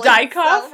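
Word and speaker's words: Diekhoff: But it's spelled D-I-E-K-H-O-F. – It doesn't matter. You know he got bullied. – Dykoff? – So it's probably Dykoff. Oh, that's Dykoff.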